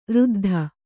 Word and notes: ಋದ್ಧ: řddha

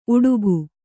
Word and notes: ಉಡಿಕೆ: uḍike

